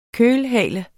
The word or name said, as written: kølhale